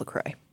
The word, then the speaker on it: LaCroix: So first of all, let's establish some pronunciation. Even we Midwesterners are in debate about the pronunciation. Is it LaCroix? Is it LaCroix? Is it LaCroix? – LaCroix? I'm going with LaCroix. – LaCroix. I called up my friend Emma's mom. Her name is Sue Wood, and she lives in Michigan. She pronounces it like this. – I think I've been drinking LaCroix for many, many years, perhaps 20. – LaCroix. – LaCroix. It is. It's LaCroix. It's pronounced LaCroix.